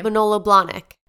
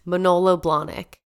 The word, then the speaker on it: Manolo Blahnik: Manolo Blahnik.